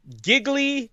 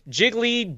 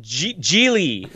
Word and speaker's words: Gigli: We are here to talk about the 2003 turkey that was Gigli, Jiggly, Gigli, rhymes with really, as we learned during this movie.